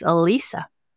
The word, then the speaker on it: Elise: And so she got my great-grandmother's middle name, which is spelled E-L-I-S-E, but it's pronounced the German way, which is Elisa.